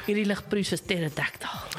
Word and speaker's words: pterodactyl: Because the P is silent. Pterodactyl.